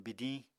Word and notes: bidiñ: Apatani

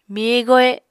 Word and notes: miye-gohe: Apatani

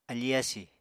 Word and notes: afí-yasì: Apatani

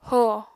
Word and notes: ho: Apatani

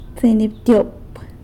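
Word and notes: tseinyabtyop: San Lucas Quiaviní Zapotec

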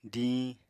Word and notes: diñ: apatani

